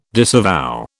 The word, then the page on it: disavow: British English